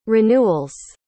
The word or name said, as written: renewals